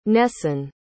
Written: Nessan

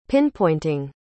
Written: pinpointing

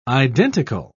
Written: identical

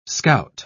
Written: scout

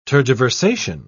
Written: tergiversation

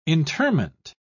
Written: interment

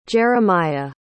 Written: jeremiah

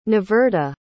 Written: niverta

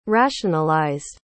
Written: rationalized

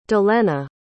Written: dalenna

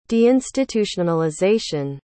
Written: deinstitutionalization

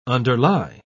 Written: underlie

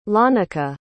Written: lanica

